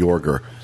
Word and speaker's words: Joerger: Actually, Fred, I think almost positive it's pronounced Jorger. Fred Jorger, J-O-E-R-G-E-R, was actually an Imagineer who is also a Disney legend.